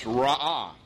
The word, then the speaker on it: ra'ah: is the word pronounced ra'ah. It sounds almost identical to the Egyptian sun god Ra.